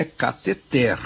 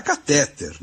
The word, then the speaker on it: cateter: O pessoal fala catéter, né? Diz catéter, mas a pronúncia erudita, de acordo com o étimo é a origem e tal, é cateter e é essa que nós vamos registrar.